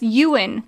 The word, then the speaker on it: Ewan: Oh, yes, Ewan.